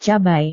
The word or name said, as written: cabai